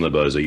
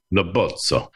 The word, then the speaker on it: Lobozzo: And we first of all have to, I mean, it's a common name, Lobozzo. You hear it all the time. But where does it originate? – It is 100% Italian. It's pronounced Lobozzo. – Lobozzo.